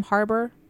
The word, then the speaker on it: Harbour: Harbour? – Yeah.